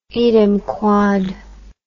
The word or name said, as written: idem quod